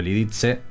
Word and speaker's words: Lidice: Y hace unos años me encontré con la historia de un poblado checo, Lidice justamente, o Lidice, si nos referimos a cómo los checos más o menos lo pronuncian.